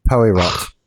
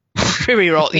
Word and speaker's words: Poirot: I believe it's pronounced Poirot. – Poirot, yes.